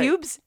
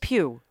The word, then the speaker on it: Pugh: How do you say pubes? It's just Pugh. – Pugh. – P-U-G-H.